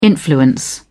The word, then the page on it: influence: British English